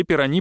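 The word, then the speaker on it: Пирони: Получив от Феррари предложение стать напарником Вильнёва вместо Джоди Шектора, молодой Дидье Пирани пытается избавиться от французского произношения фамилии с ударением на последний слог.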